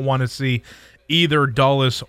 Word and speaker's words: Dulles: Dulles. – Yeah, I always overthink it. Washington Dulles International Airport after the former president.